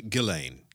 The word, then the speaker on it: Ghislaine: Ghislaine. Ghislaine. – Ghislaine. – Ghislaine. It's like Elaine.